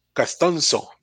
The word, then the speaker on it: Castonzo: Anthony Castons Castonzos, Castonzo, perdón. Castonzo. Castonzo.